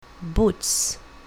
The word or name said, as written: boots